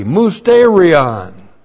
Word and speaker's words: mysterion: Listen, I tell you a mystery, mysterion. And the rapture is mystery doctrine. M-U-S-T, long E-R-I-O-N.